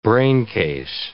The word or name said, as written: braincase